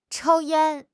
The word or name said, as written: chou1 yan1